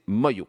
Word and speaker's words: Manglo: Et on va terminer ce segment avec Écouter tous mon histoire, interprétée par un groupe qui s'écrit Manglo, M-A-N-G-L-O, mais paraît-il, faut prononcer Moyo.